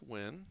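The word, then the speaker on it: Nguyen: It's Win. It's not that hard. It looks hard, but we just say Win.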